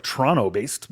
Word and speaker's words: Toronto: Okay, so he's a Canadian author, Toronto-based, I think, Toronto-based, I'm not supposed to pronounce that second T, who I really like, who I discovered him in the way that people discover things that already exist.